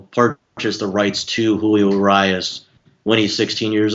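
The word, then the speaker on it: Urías: To get people caught up to speed if you missed it, and am I pronouncing that right, Urias? – Yeah, Julio Urias, he was a pitcher who signed out of the Mexican League.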